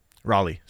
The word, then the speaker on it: Raleigh: So initially found guilty, and he would be transferred to Raleigh. Is that how you say it? – Raleigh, sorry.